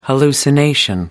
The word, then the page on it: hallucination: American English